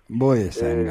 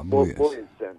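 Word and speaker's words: Bojesen: Şimdi karikatürü Nils Boyesen, doğru telaffuz ediyor muyum bilmiyorum ama. – Boyesen galiba. – Boyesen, evet.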